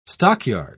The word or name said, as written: stockyard